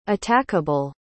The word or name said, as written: attackable